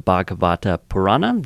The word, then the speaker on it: Bhagavata Purana: He's the author and also edited several books, one of them being an abridged translation of the Bhagavata Purana. Did I pronounce that correctly? Very good, yes.